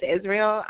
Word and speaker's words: Israel: It's Israel, I-S-R-A-E-L, and then my last name is Lopez.